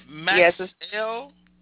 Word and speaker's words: Maxiell: Is it Maxiell? – Yes.